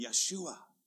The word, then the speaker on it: Yeshua: Name him with that name, or as some people uh, like to pronounce it in a more Hebrew way, Yeshua literally means Yahweh saves.